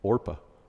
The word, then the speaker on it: Orpah: Almost said it wrong. Orpah.